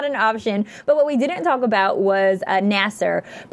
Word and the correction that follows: Nasir: protecting, am I saying Nasir? It right? Okay. – Nasir.